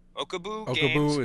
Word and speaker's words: Okobu: Okobu is how I pronounce it.